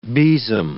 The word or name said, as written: besom